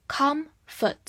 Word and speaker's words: comfort: 因为这个单词的重音在第一个音节上面 com，所以后半部分是弱读，我们发 a 这个音，所以是 comfort。 comfort 不要读成 comfort。